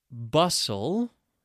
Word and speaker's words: bustle: Okay, so what are some other common words with a silent T? How about ballet? B-A-L-L-E-T. Hustle? H-U-S-T-L-E. And its friend, bustle? B-U-S-T-L-E.